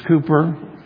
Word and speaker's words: Cowper: It's spelled Cowper, but it's Cooper.